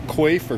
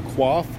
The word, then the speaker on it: coif: It's a basic coif or quaff, depending on how you want to pronounce that, pattern.